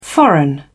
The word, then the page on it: foreign: British English